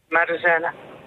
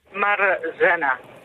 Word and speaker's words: Marzena: Marzena. – Zeg ik het goed? Is het Marlena? – Marzena. – Ah. – Marzena. – Oké.